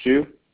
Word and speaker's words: Xu: Yeah, this is Mike Xu.